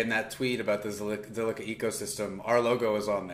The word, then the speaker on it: Zilliqa: But yeah, no, I think it's interesting too about Zilliqa space. I've been pronouncing it wrong forever. It's Zilliqa, not Zilliqa.